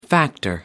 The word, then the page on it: factor: American English